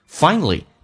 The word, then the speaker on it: finally: Finally, 这个单词在美式发音当中跟英式不一样，英式发音叫 finally，美式发音叫 finally。因为当一个 a 碰到一个 l 的时候，在美式发音要被吸收，就是 a 不发音，所以它变成 finally。